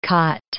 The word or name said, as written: khat